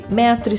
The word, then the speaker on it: Maitre: Lembrando que Metre se escreve Maitre, né? E Week W-E-E-K, tá bom?